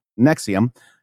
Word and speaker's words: NXIVM: Needless to say, we've been obsessed with the Albany-based NXIVM, pronounced, believe it or not, Nexium, ever since.